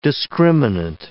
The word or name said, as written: discriminant